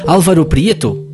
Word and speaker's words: Álvaro Prieto: So, Álvaro Prieto, is it like this?